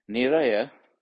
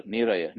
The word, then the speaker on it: Niraya: N-I-R-A-Y-A. Niraya. Next word, Apaya, A-P-A-Y-A, Apaya. Now, Niraya, that's how you form this word, Niraya. Ni, N-I, plus I, N I ni, prefix ni, plus I, Y A I. I think that's good for the Pali pronunciation class as well here.